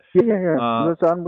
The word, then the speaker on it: Lausanne: The other one, in my mind anyway, is the Treaty of Lausanne. And I'm not sure I'm pronouncing mm-hmm. that right. But Lausanne would do, yes.